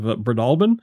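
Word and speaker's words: Breadalbane: Campbells of Breadalbin.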